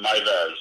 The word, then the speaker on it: Meiwes: and we want you back on our show too, because uh, I saw that you have some things to say about Armin Mule. how do you say his last name? Armin. – Armin Meiwes. Maivez.